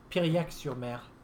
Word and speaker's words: Piriac-sur-Mer: She's, um, I can't pronounce that either, I think. – Piriac-sur-Mer. – Pierre-sur-Mer. Thank you. You're much better Frenchman than I am.